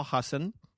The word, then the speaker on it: Hasan: I got two comedians, Hasan Minhaj, who I call Hassan. That is the correct pronunciation, even though he told me that he's used to everyone saying Hassan. So when he hears Hassan, he's like, is my mom calling me? That's the correct pronunciation of his name.